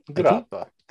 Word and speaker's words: Grappa: Grappa, if I said that correctly. – Grappa, thank you.